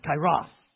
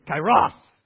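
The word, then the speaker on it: kairos (καιρός): It's kairos. Everyone say kairos. Okay, now the emphasis is on the second syllable, so you got to say it with some gusto, right? Everyone say kairos.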